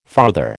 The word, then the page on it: farther: American English